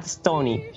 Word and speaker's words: Stonie: Se escribe M-A-T-T, -T, ¿cómo suena? S-T-O-N-I-E, Matt Stoney y el hueón es el campeón mundial de, comer cantidades absurdas de comida.